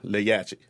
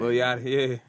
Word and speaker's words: Lil Yachty: Lil Yachty. – I don't know how to say his name. – Lil Yachty, yeah.